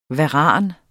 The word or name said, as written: varan